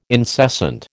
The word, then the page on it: incessant: American English